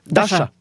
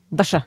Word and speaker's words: Daša: Nie, ja netuším, že vôbec existuje meno, ktoré sa vyslovuje Daša. – Daša.